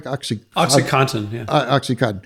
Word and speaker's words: OxyContin: And then when it became addictive, the OxyContin people said, am I saying that right? – OxyContin.